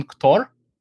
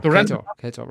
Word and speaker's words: Ktor: So I saw a try to use Jetpack Compose on Ktor to render something on Ktor. – Oh, Ktor. Ktor, right. – Yeah, yes. Yeah, Ktor. Yeah. Sorry for my pronunciation.